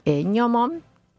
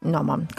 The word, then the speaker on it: gnomon: gnomon, o gnomon, como se escribe G-N-O-M-O-N, ¿sí? Es gnomon o gnomon,